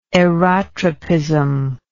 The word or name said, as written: aerotropic